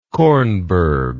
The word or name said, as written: kornberg